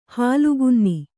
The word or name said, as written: ಹಾಲುಗೆನ್ನೆ